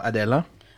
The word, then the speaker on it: Adella: So Adela.